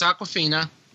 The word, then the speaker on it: Awkwafina: No, it's Awkwafina.